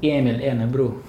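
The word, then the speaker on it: Ernebro: По- шведски по-шведски его зовут Эмиль Эрнебру. А, э, еще раз, Эмиль Эрнебру. Эмиль Эрнебру. То есть Эрнебру. А, то есть в, Р, там, там два... Практически не считается. – Два ударения, типа Эрнебру. – Ну, это так, так здесь произносится, не знаю, как это правильно писать фонетически. То есть Эмиль и Эрнебру. То есть он на конце в шведском читается как У.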